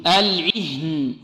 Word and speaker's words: العهن: انطق معي العهن. – العهن. – كالعهن المنفوش. – كالعهن المنفوش. – والها هذه كذلك لابد من إيضاحها، العهن هكذا المؤمن حافظ على كسرة العين بارك الله فيكم.